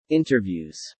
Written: interviews

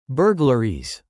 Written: burglaries

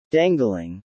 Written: dangling

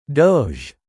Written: Doge

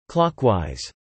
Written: clockwise

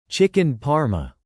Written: chicken parma

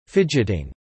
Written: fidgeting

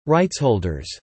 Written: Rightsholders

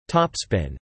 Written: topspin

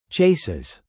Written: chases

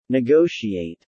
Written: negotiate

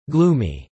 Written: gloomy

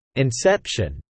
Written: inception

